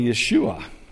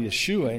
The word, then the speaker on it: Yeshua: And it would be pronounced probably Yeshua. And in our language, we would say that's how Jesus' name was probably Yeshua in Hebrew.